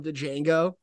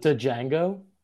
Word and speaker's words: Django: You just call it Django? – Yeah, he did. – You just pronounce the D in Django? – Django, whatever, I don't know.